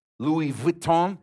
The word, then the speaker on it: Louis Vuitton: Louis Vuitton, that's how you say it, bag, cash, or a supreme brick.